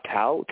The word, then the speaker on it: Tal: Tal, T-A-L. My legal name's Talmai. It's T-A-L-N-A-I.